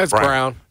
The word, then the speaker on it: Braun: Christian Braun, those guys, Braun, how do you pronounce it? – It's Brown. – It's Brown, yeah. Spelled Braun. – Yes.